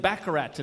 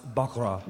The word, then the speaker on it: baccarat: No trouble, Blofeld, just haven't played baccarat in a while. – Bond, it's pronounced baccarat. – Baccarat. – Right, of course, baccarat.